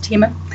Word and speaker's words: Thieme: It's pronounced Thieme.